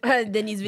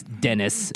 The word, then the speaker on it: Denis: Denis? – Denis Villeneuve. – Or to Americans, it's Dennis Villeneuve.